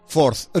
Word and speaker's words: fourth: Fourth. No, fourth. Four es cuatro, pero sí. – Cuando es las fechas se pone ordinal. Entonces, fourth, que sería el cuarto de mayo.